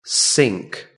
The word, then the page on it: sink: British English